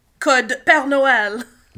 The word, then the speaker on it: Code Père Noël: A.k.a. 36 Games Code Père Noël. – That's how you say it.